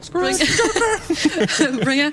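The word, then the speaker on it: ScourgeBringer: nämlich Scorchbringer, wenn ich das richtig ausspreche. – Mhm. – Und das starten. Ich mach mal Sound kurz noch an. – Sperriger Titel. Guck mal. – Ja, Scourge. Scourge. – Scourge. – Scourge. Bringer. das ist ein Roguelike.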